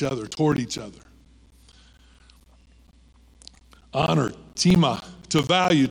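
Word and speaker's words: tima (τιμή): Time is the word, but it's pronounced tima. Spelled time, pronounced tima.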